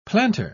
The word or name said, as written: planter